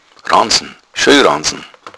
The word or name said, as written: ranzen